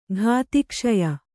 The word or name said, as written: ಘಾತಿಕ್ಷಯ